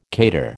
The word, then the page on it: cater: American English